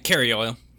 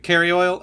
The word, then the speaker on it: Kerioyl: Um, did you ever come to a conclusion on how to pronounce Kerioyl's name? Uh, Carrie Oil, yeah. C- Carrie Oil?